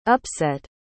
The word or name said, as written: upset